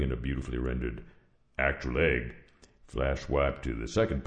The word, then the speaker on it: AIG: And how would you pronounce those 3 letters? – Well, I guess egg. But... – And after we've spent $175 million in a campaign so viral it would literally make your head spin, you join the 210 million Americans who pronounce it egg.